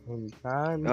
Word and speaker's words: Encanto: Oh, El Encanto. Encanto. – Oh. I was fucking butchering the shit out of that thing.